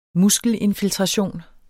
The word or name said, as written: muskelinfiltration